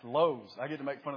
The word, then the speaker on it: Lowe's: He said, "I don't know how to pronounce it." I said, "It's Lowe's." I said, "You're the weirdo now."